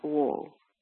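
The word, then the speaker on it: Wang: Vergine Martire, l'ho letto, mi sono documentata e si legge proprio così, Sant'Anna Uo, Vergine Martire, sì, nata in Cina nel 1886 da una famiglia di poveri contadini cristiani.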